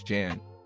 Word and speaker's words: Jan: My my real name is Jan, like Jan Brady, yeah.